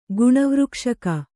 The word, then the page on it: ಗುಣವೃಕ್ಷಕ: guṇavřkṣaka; Kannada